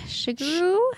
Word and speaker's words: Shigeru: Shigeru.